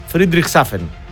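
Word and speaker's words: Friedrichshafen: Καλημέρα και στον φίλο του Σάκη, που μας ακούει από τη Γερμανία, από την όμορφη λίμνη του Φρίντισχαφτού στο διεθνές. Γερμανία, Αυστρία, Ελβετία. Δεν διαβάζεται αυτό, ρε φίλε. Ναι, ναι, υπέροχα ψάρια. Δεν διαβάζεται αυτό, ρε φίλε. Φρίντριχσάφεν.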